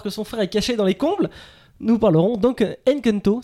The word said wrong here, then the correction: Encanto: c'est comme ça que ça se prononce Encanto. – Encanto.